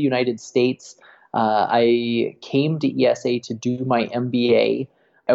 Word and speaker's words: IESE: As you know, in Spanish, it's pronounced IESE Business School.